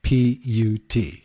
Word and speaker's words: Chaput: Do you spell it? – Chaput. – Caput. – No. – Okay. No. Anyway, Chaput.